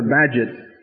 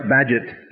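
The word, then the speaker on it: Bagehot: In the 19th century, when banks were collapsing a lot, it was a real pleasure, Walter Badgett, as far as I'm concerned, one of the most overrated political theorists and economists in the history of the world. Anyway, Walter Badgett, beloved by almost every historian, some obscurities.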